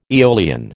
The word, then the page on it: eolian: American English